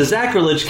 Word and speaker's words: Zachrilege: It's the Zachrilege Cast, Z A C H R I L E G E, and I can spell it after two beers, so you can too.